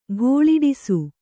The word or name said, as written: ಘೋಳಿಡಿಸು